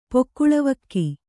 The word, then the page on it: ಪೊಕ್ಕುಳವಕ್ಕಿ: kannada